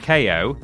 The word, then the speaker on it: KO: It's pronounced KO. KO because he's got boxing gloves, you see? KO.